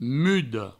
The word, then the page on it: muda: mudo